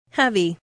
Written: heavy

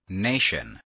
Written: nation

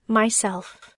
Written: myself

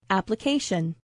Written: application

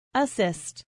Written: assist

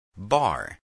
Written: bar

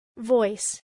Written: voice

